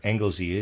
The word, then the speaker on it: Anglesey: Reader's aside, it's spelled A-N-G-L-E-S-E-Y, Anglesey, I'm going to say, end of reader's aside.